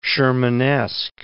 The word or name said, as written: Shermanesque